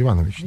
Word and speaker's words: Иванович: Правильно я произношу? – Иванович, да.